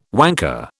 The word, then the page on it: wanker: British English